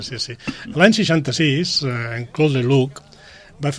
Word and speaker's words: Claude Lelouch: Claude Lelouch.